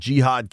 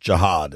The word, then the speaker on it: Jihad: Norris has the Commanders taking Jihad Campbell, or maybe I've read that I think it's they want it pronounced Jihad instead of Jihad, which has a different connotation, certainly.